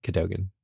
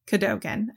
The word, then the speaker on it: Cadogan: but I think a lot of official audiobooks and things say Cadogan. – Cadogan. Okay, we're going to go with Sir Cadogan.